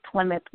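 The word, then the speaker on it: Clemith: Or they can find me on Facebook under my name, which is Plymouth Washington.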